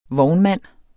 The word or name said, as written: vognmand